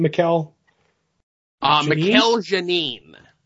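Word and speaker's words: Mikel Janín: Written by Tom King and drawn by Michael, how do you say his name? – Mikel Janine. – That's what I say, too.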